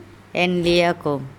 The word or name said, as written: enliakom